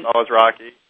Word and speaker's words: Alazraki: So, and then, you know, there was another actor, and I'm really afraid to mispronounce his name, Carlos Alazraki. Okay. – Alazraki.